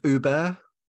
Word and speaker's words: Uber: It's pronounced Uber.